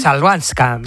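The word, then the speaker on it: Chwalińska: Joder, ¿cómo se dice la es complicada, ¿eh? Chabalinska. – ¿Eh? Chalvanska. – Chalvanska. – Maya Chalvanska.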